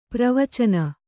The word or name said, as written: ಪ್ರವಚನ